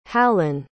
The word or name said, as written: hallan